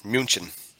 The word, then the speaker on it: München: First of all, pronounced like a German, München. Come on. – Oh, Munich.